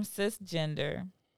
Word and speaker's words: cisgender: The term cisgender, pronounced cisgender, like cis, like, hey, sis.